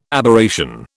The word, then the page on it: Aberration: British English